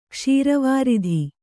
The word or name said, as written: ಕ್ಷೀರವಾರಿಧಿ